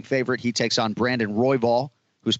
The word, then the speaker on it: Royval: Royval.